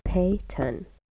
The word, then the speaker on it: Peyton: When I say my own name, I say Peyton. Like, I just kind of, like, it flows together, Peyton. And everyone's like, I thought you said pin, I thought you said ping, I thought you said whatever, something else starts with P.